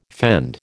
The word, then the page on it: fend: American English